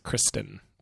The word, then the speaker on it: Christine: Although I think he pronounced it Kristen, but it was spelled Christine.